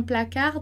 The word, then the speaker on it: placard: E como eu falei, a gente não vai pronunciar nem o S, nem o T e nem o D no final de palavra. Então, vai acontecer a mesma coisa ali pro placard. Muito bem, então a gente passa para a próxima palavra que é bonsoir.